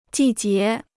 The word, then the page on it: 季节: jì jié